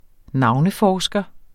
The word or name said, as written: navneforsker